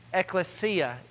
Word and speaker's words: ecclesia: The word, just so you know how it's pronounced, because you drive by it every Sunday, ecclesia is the word. All right, everybody goes, "Oh, so that's how you say it."